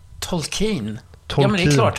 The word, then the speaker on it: Tolkien: Och jag har i hela mitt liv sagt Tolkien. Han heter Tolkien.